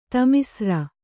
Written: ತಾಮಿಸ್ರ

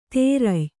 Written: ತೇರಯ್

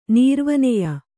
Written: ನೀರ್ವನೆಯ

